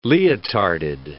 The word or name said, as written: leotarded